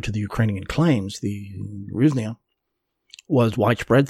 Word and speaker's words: hryvnia: The current currency of Ukraine is a hryvnia. Ukrainians will spell it with in Latin letters, they'll spell it with an H. Russians will spell it with a G, the G sound.